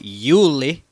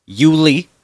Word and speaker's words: Yulee: there was a YouTuber that did it, and he did not pronounce it Yuli, Yuli, which is how they pronounce it here, believe me. Mm-hmm. He pronounced it Yuli, Florida.